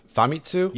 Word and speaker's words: Famitsu: Famitsu? – Yeah.